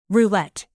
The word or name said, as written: roulette